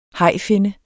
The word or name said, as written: hajfinne